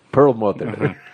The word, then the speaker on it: Perlmutter: Ike Perlmutter. ¿Lo dije bien? – Perlmutter.